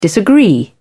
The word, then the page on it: disagree: British English